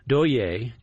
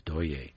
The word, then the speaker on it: Douye: Yeah, we started off with another new album, that one from vocalist Doye, D-O-U-Y-E, with an emphasis on the E.